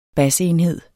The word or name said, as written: basenhed